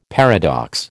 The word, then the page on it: paradox: American English